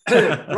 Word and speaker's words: Gowran: Make sure you pronounce Gowran right, though. Not known as Gowran, shut. – Gowran. Yeah. But yeah, looking forward. Gowran.